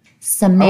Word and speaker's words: Sammamish: Sammamish.